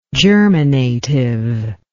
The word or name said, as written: germinatively